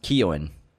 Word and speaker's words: Keoghan: It's actually pronounced Keoghan.